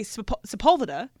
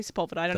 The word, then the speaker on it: Sepulveda: So I say Sepulveda. – That's right. – That's how you say Sepulveda.